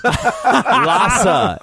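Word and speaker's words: Lasse: Lassa. It's Lassa Hallström. – No. No, yes, it's Lassie.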